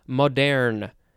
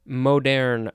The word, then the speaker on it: moderne: And the last word for this episode is art moderne. Moderne, or I think it's moderne, because it is spelled M-O-D-E-R-N-E.